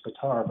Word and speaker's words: Bitter: We have a piece, Carved Angel by Bittar, B-I-T-T-E-R. And it's not pronounced bitter. It's not pronounced bitter. It's Bittar, but it's with an E.